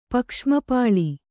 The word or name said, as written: ಪಕ್ಷ್ಮಪಾಳಿ